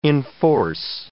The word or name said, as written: enforce